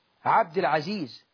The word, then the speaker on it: عبد العزيز: اسمه عبد مش عب، عبد العزيز، عبد الوهاب، مش ربنا مش اسمه وهاب، قل وهاب مش وهاب.